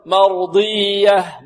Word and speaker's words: مرضية: والياء هنا مشددة فيها نبر، مرضية، لا نقول مرضية مرضية ضية، لا هذا ليس إدغاما، هذا إظهار، مرضية، لا لابد من النبر والضغط والتشديد، راضية مرضية مرضية، ولا نقول مرضية ضجة هكذا، لا هذه شدة خطأ، وإنما ننطق الياء مشددة فيها ضغط وكذلك ماذا؟